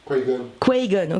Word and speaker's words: Qui-Gon: Qui-Gon, ok.